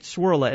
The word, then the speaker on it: Cwirla: And all you've got to do is go to Google, click on, just write Swirla, and it's spelled C-W-I-R-L-A. Just where you would put an S, put a C. C-W-I-R-L-A.